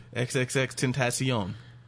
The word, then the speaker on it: XXXTentacion: I thought so when you kept saying XXXTentacion, I thought you were joking. And then I found out that's really how you say it. – Yeah. – XXXTentacion.